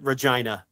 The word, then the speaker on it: Regina: It's 1,000% Regina, because I obviously remember that for obvious reasons. Right. Because I'm a child and it rhymes with, you know, vulva.